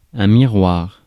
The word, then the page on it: miroir: /mi.ʁwaʁ/; /mi.ʁwɑʁ/